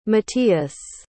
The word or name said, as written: Matías